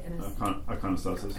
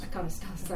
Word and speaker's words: iconostasis: Iconostasis. – Iconostasis, I was pronouncing it wrong.